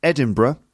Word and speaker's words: Edinburgh: So the Edinburgh Festival, and yes, it's pronounced Edinburgh, not Edinburgh or Edinburgh.